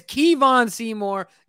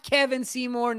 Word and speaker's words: Kevon Seymour: He is Kevon Seymour, not Kevin Seymour, not Kevon Seymour. Kevon Seymour, he is here to stay.